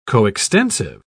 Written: coextensive

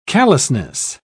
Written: callousness

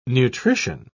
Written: nutrition

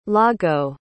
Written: Lago

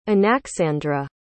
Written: Anaxandra

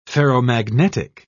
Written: ferromagnetic

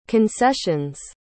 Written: concession's

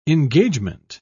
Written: engagement

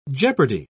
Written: jeopardy

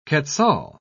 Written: quetzal

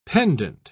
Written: pendent

